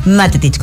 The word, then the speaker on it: Matetich: Matetich, con acento en la A.